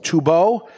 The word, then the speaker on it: Tubeaux: Tubeau. – T-U-B. I know you love me when I spell. T-U-B-E-A-U-X. – I'm thinking Tubeau. – Yeah, the X is silent.